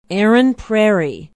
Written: Erin Prairie

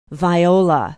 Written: viola

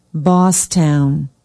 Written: bosstown

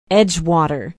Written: edgewater

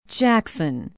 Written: jackson

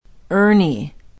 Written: urne

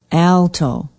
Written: alto

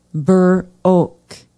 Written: burr oak

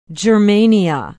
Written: Germania

